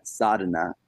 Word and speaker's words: sadhana: What is it? A sadma? A sadha? What is it? – Yeah, sadhana.